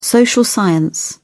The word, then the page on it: social science: British English